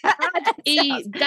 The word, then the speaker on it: Adidas: No, I'm right. It's Adidas. – Adidas. – Not Adidas.